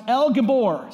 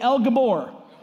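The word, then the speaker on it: El Gibbor: Now, Mighty God, you know, is a Hebrew word that's actually pronounced El Gabor. Say El Gabor.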